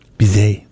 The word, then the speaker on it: Bizet: That was the London, that was from Bizet's Carmen. – Bizet with a T. – Georges Bizet with a silent T.